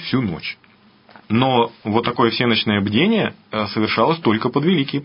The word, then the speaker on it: всенощное: Да, действительно, древние христиане, да и мы с вами, знаем такое богослужение, как всеночное бдение, которое по-славянски звучит как всенощное. Правильно было бы ударение ставить. Ну, вот у нас в обиходе уже так принято всенощное.